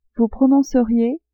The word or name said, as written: vous prononceriez